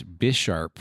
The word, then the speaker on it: Bisharp: I'm a huge Bisharp fan. Mm-hmm. It was my favorite Mon until Lycanroc came out. And when you guys mentioned more chess pieces, my mind jumped instantly to an evolution to Bisharp with king-like qualities.